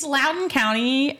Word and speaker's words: Loudoun: It's spelled like it shouldn't be pronounced like that. – It does. It's an L-O-U-D-O-U-N. So it's the same. O-U. Yeah. It's Loudoun County.